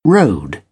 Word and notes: rode: British English

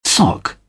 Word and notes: sock: British English

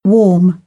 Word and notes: warm: British English; ɔː

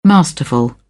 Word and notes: masterful: British English

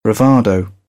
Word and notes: bravado: British English; əʊ

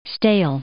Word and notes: stale: adjective

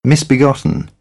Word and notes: misbegotten: British English

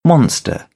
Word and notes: monster: British English; ɒ

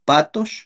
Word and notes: patox: Náhuatl

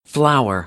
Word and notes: flower: American English; ɚ - əɹ

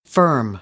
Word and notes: firm: American English; ɝː - ɜː

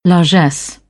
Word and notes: largesse: British English

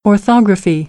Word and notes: orthography: American English

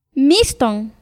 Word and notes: miston: Náhuatl

